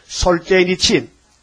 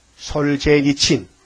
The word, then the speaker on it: 솔제니친: 소련의 시인 솔제니친. 솔제니친. 맞아? 발음 맞았어? 솔제니친 맞아? 내가 혀가 좀 짧아서 발음이 안 돌아간다. 솔제니친. 맞아요? 시선 맞아? 응, 솔제니친이.